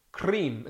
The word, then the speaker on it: Krim: Krim is pronounced as in krim. cream, as in cream in English, rolling the R as in Spanish.